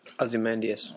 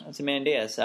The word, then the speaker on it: Ozymandias: Ozymandias. – Ozymandias.